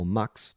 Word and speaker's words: Mx: MX, pronounced or to sound like Mix or Mux.